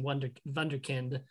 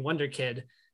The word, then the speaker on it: Wunderkind: And, you know, what Nate gets instead is he gets made fun of for mispronouncing Wunderkind and saying Wonder Kid.